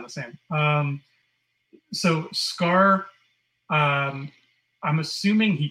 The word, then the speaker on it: Skaar: Scar, S-K-A-A-R. – It's like Sakaar, yeah. It's like Scar, but it's spelt in a weird way.